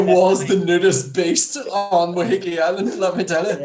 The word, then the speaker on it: Waiheke: He married his second wife, Fiona, in a secret ceremony in Waiheke Island. And I know that's the way it's pronounced because I was there.